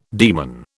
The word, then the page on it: demon: British English